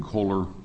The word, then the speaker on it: Kohler: Yes, Mr. Chairman, my name is Jim Kohler. Last name is spelled K-O-H-L-E-R, Deputy Director, Enterprise Technology Services with the Department of Administration.